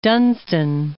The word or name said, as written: Dunstan